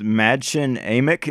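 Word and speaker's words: Mädchen Amick: I think it was Madchen Amick is what we landed on the pronunciation, who played Sarah Collins.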